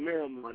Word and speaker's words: Miramar: Oh, I'm from Miramar.